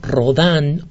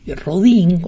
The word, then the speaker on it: Rodin: Y la imagen más asombrosa que yo he visto, más impresionante que yo he visto, es la que hizo el gran escultor Rodin, o Rodin, si lo pronunciamos en español, el famoso escultor del pensador, del famoso pensador. Rodin, Rodin, pronunciado en francés, fue amigo de Pedro Julián Aimart.